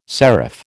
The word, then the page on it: serif: American English